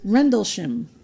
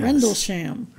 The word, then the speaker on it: Rendlesham: Rendlesham. – Yes. – Rendlesham. – Rendlesham.